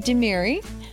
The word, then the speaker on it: Demiri: But today I have Silvi, Demiri.